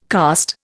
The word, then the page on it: cast: Indian